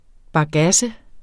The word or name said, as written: bagasse